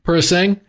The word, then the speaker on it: Purosangue: Purosangue.